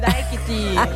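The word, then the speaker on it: Dakiti: Und jetzt für euch nochmal Bad Bunny mit Daikiti. Habe ich es richtig ausgesprochen? – Si, Daikiti.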